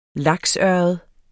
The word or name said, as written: laksørred